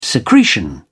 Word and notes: secretion: British English

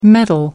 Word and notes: metal: American English

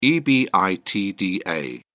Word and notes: ebitda: British English

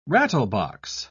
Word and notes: rattlebox: British English

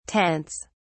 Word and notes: tents: British English

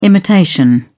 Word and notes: imitation: British English

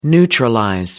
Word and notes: neutralize: American English

